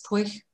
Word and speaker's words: Pwyll: There's this one name that's spelled P-W-Y-L-L. I would have said Pwyll or something, but it's Pwych. Because Welsh is very strange.